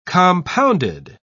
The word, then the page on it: compounded: British English